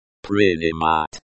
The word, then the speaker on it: Принимать: Принимать. Принимать. The stress falls on the third syllable.